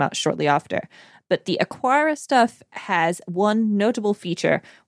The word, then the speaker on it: Aqara: Yeah, I'm going to pronounce this Aqara, even though it's not got a U in it.